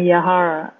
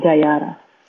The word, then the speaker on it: Yahara: En inglés me quieren decir Yahaira, pero mi nombre no es Yahaira, y entonces en inglés acepto que me digan Yahara, y en español, pues mi nombre es Yayara.